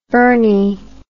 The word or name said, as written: burny